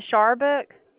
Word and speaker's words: Sharbutt: H-U-N-T-E-R. – Yes, ma'am. – And the last name? – Sharbutt. – You said Sharbutt? – Yeah, Sharbutt. – And spell that for me. – S-H-A-R-B-U-T-T. – Okay. S-H-A-R-B-U-T-T.